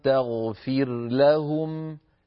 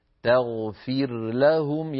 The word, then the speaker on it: تغفر لهم: تغفر لهم ها هنا اجتمع في النطق راء ساكنة في تغفر ولام في لهم تغفر لهم فبالنسبة للقراء ما عدا أبو عمرو البصري فإنهم يظهرون هذه الراء عند اللام فيقولون وإن تغفر لهم تغفر لهم يعني